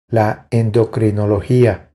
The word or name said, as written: La endocrinologia